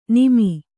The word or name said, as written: ನಿಮಿ